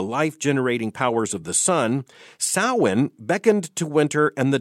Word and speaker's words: Samhain: It is spelled S-A-M-H-A-I-N, but pronounced Samhain.